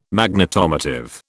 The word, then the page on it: Magnetomotive: British English